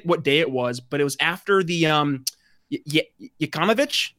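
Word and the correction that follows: Yovanovitch: How do you say her name? She was the former Yovanovich.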